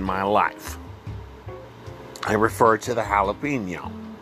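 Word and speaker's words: jalapeño: My adventures with the jalapeno pepper, and that is how it is pronounced, my friends, jalapeno.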